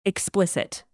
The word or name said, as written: explicit